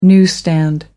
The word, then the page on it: newsstand: American English